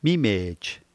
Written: mimej

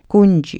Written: knuji

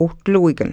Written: wtluikn

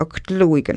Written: ktluikn

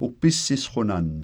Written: wpisisqunann

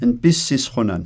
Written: npisisqunan

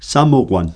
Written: samuqwan

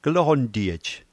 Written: kloqntiej

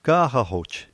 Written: kaqaquj

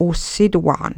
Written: wsituaqn

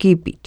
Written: kipit

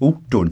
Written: wtun

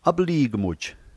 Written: aplikmuj

